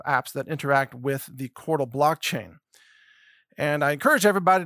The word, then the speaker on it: Qortal: And Cordal, which is spelled Q-O-R-T-A-L, by the way, Cordal is a decentralized platform for content publishing.